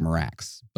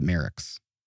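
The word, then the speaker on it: Marax: It looks like Merax, but they say it's pronounced Merix.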